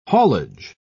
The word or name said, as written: haulage